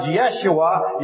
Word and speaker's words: Yeshua: Whenever you say Jesus, you are really saying an anglicized Yeshua.